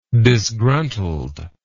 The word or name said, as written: disgruntled